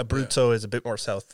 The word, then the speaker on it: Abruzzo: So my grandparents are from the provinces are Marche and Abruzzo, which are they're kind of neighboring provinces.